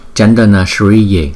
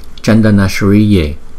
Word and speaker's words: Chandana Shriye: Chiên Đàn Công Đức Phật chúng ta sẽ phát âm là Chandana Shriye, Chandana Shriye, Chandana Shriye, Chandana Shriye. Chữ S chúng ta phải đọc cong lưỡi lại và lấy cái hơi từ trên vòm miệng chúng ta đưa ra.